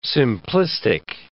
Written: simplistic

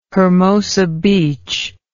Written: hermosa beach

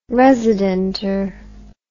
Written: residenter

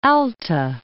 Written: Alta California

